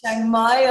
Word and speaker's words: Chiang Mai: She's also certified as a Thai masseuse with the Medicine School of Chiang Mai. Is that right? Chiang Mai up in the north.